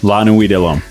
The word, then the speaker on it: La Nuit de L'Homme: La Nuit de L'Homme.